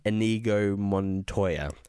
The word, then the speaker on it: Inigo Montoya: Inigo Montoya.